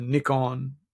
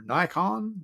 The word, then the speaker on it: Nikon: So I bought a Nikon or Nikon, depending on how you like to pronounce it, D7000.